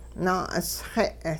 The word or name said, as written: naa'usxe'